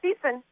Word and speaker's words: Thiessen: She is, and am I saying that right? Is it Thiessen? – Yeah, Thiessen. You got it.